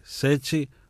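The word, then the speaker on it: Sete: Γράφεται Σέτε, προφέρεται Σέτσι, παρατσούκλι που το πήρε από τις 7 μπάλες του μπιλιάρδου.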